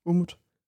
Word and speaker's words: Umut: Umut eller Umut? Umut. Umut? Ja.